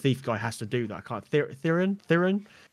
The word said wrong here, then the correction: Therion: how you pronounce his name, Therion.